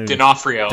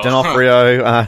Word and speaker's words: D'Onofrio: D'Onofrio. D'Onofrio in the fourth one.